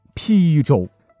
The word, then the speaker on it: 邳州: 我是打死不信的哈，于是就挑战了一把，结果是，哎，我只认出了一个，哈哈，这便是其中的邳州。邳州很多人会错念成邳州，邳是左边不字下面加一横，曹丕的丕，右边是个耳朵旁。